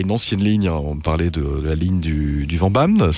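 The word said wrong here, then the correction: Vennbahn: c'est ça? – De la Fennbahn, oui, tout à fait. – Ouais, pardon, ça prononce comme ça, ok.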